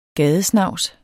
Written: gadesnavs

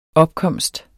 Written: OPKOMSTERNES

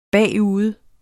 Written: bagude

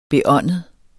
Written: beåndet

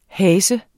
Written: hase

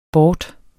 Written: bort

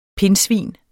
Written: pindsvin

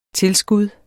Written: tilskud

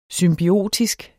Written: symbiotisk

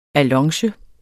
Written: allonge

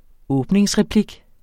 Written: åbningsreplik

